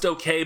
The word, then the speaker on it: OKeh: OK is spelled O-K-E-H. So it's pronounced okay,